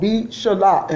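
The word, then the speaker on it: B'shalach: It's called B'Shalah, and that is in the Hebrew meaning when he let go.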